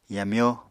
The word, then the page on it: yamyó: Apatani